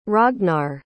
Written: ragnar

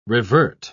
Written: revert

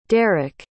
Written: darek

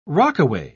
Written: rockaway

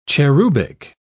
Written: cherubic

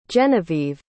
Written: jennavieve

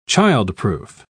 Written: childproof

